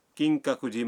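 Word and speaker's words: کنگ ککوجی (金閣寺): مثلاً آپ کیوتو کے کنگ ککوجی مندر جانا چاہتے ہیں. مندر کے نام کا تلفظ ہے کنگ ککوجی.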